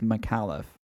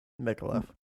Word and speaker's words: Micallef: I assume it's pronounced Micallef. – Micallef. – Right, that is what, to me, that is what I would think is how you pronounce it.